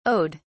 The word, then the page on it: owed: British English